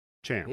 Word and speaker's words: CHAM: And not only that, but we see that her band, Sham, I'm not sure how to pronounce it, has broken. – Yeah, I think it's Cham. – Cham.